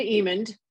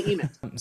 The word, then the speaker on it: Emmond: Hey, Linda Emmond. But that's okay. – Emmond, mispronounced, but that's cool. That's okay. It's also often mispronounced Edmund, and people add a letter in there. But anyway, it's Linda Emmond.